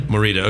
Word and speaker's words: Morita: I don't want to make Morita. – Morita, okay.